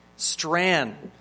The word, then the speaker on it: Strachan: This is Strain, and just correct me, I originally mispronounced his name, Strachan, but I believe it's Strain. Is that correct? One more time? Strain.